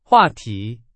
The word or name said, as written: 话题